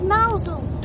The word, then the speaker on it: Mousehole: It's spelt Mousehole, but they call it Mousehole.